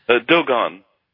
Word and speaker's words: Dogon: But how did you come across and am I pronouncing this tribe right, the Dogon tribe? Do they pronounce it like that? – Dogon.